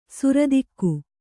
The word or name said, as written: ಸರಿದೂಗು